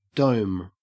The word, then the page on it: dome: Australian English